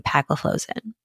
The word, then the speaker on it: empagliflozin: Canagliflozin, dapagliflozin, and empagliflozin. All right. So with that, nice job with the pronunciation.